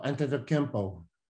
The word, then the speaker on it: Antetokounmpo: Oh, Antetokounmpo.